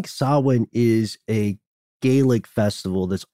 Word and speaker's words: Samhain: And if you want to sound smart in front of your friends, or at the very least not sound dumb, it's not pronounced Samhain. It's pronounced Samhain.